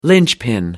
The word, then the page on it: lynchpin: American English